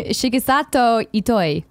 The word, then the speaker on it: Shigesato Itoi: A similar interview with Shigen Sato Ito, creator of Earthbound. What? Did I totally butcher it? – It's okay. Just keep going. – Do it. No, say it right. – Shigesato Itoi. – Thank you.